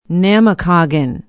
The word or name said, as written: namakagon